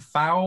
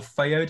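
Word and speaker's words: Phao: It's called Sappho and Fao, or Feo, depending on how you pronounce it, by someone called John Lilly.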